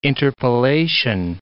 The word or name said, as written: interpellation